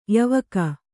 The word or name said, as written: ಯಾವಾಕೆ